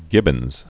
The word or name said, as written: gibbons